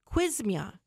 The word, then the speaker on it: Qsymia: And these medications, some of them, one of them is called, and I'm going to spell it because it's called Q-S-Y-M-I-A, Quizmia.